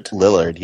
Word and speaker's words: Lillard: And it's got Matthew Lillard? – Lillard, yeah.